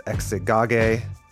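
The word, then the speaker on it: Exagoge: It's a work by Ezekiel the Tragedian, and it's called Exegage. That's at least how Tim told me to pronounce it.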